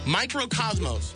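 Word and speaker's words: Mikrokosmos: Microcosmos. That's how you pronounce it, right? – Yes.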